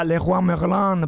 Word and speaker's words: Leroy Merlin: No, io invece sono molto dispiaciuto che non posso più andare da Leroy Merlin, perché adesso so che lo dico male, quindi non entrerò più in quel negozio. – No, invece l'hai detto bene adesso, vedi, grazie a te.